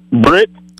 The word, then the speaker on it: Britt: Britt. – Spell it. – B-R-I-T-T.